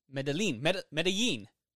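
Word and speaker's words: Medellín: This is the 1980s, 1990s, and it's all about the, is it Medina cartel? Medellin. Say it for me. I can't say Spanish. – Spell it. – M-E-D-E-L-L and the I with a little thing, mark.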